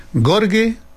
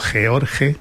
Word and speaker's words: Gheorghe: Gheorghe, escrito con muchas H's de por medio, George, Gheorghe se pronunciaba,